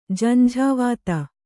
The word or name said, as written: ಜಂಝಾವಾತ